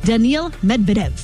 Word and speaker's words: Daniil Medvedev: He is headed to the quarterfinals, defeating 7-seed Daniil Medvedev.